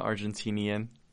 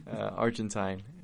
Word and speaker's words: Argentine: So for those that don't know, which I assume everyone, I'm Argentine. And that's how you say it, not Argentinian. Argentine.